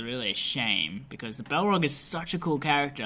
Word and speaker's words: Balrog: Balrog? – Balrog.